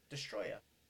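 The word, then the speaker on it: Destoroyah: Destroya.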